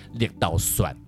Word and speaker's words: 绿豆蒜: 哦，很多人都念绿豆蒜，蒜对，是因为我们刚才有有解释过了，这次再解释一次，是绿豆蒜其实它是闽南语发音绿豆蒜，